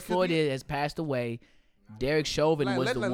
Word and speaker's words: Chauvin: Yeah, Chauvin. – Yeah, him. Like, it's about him. – Make sure we pronounce his name right.